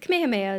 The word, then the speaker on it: Kamehameha: Well, yeah, I mean, you could, Kamehameha is correct.